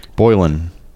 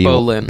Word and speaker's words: Boleyn: Boylan. – Okay. – The Other Boylan Girl. – Boylan? – B-O-L-E. – Boleyn.